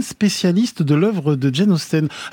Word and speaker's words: Austen: Mais bon, Jane Austen, c'est vraiment le E là, est entre le I et le E.